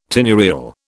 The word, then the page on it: tenurial: British English